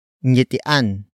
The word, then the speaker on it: ngitian: Ngitian ang sanggol.